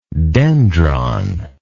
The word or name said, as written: dendric